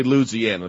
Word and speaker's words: Louisiana: A Louisiana driver, I should say Louisiana, that's the proper pronunciation.